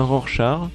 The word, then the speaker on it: Rorschach: Je rêve où il a dit patch. Non, j'ai dit patch, Rorschach. Non, comme tu vois, j'ai fait beaucoup d'efforts sur la prononciation des pseudos. Donc toi, je dis bien Rorschach.